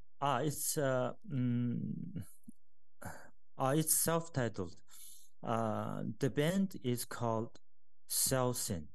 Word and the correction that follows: Saosin: I don't know if I'm pronouncing it right. I will write it down for you. – Okay. – Saosin. Saosin.